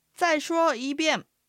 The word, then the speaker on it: 再说一遍: 在は下がる四声、说は一声、一遍はいが二声、遍が四声、四声、一声、二声、四声の組み合わせになります。まあの発音だけでアクセントの流れを確認すると、まあまあまあまあということになります。では練習してみましょう。 – 再说一遍。